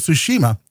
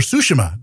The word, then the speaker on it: Tsushima: BGA this week, I'll be on, so tune in tomorrow as we cover Ghost of Tsushima or Tsushima, depending on which character in the game is pronouncing that island.